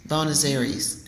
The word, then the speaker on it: Buenos Aires: Buenos Aires. Am I saying it right? – Yeah. – Buenos Aires.